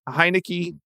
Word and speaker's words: Heinicke: But if I'm looking at the quarterback depth chart, I have never seen Taylor Heinicke do his thing, and I hope I'm pronouncing his name right.